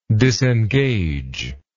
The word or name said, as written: disengagedness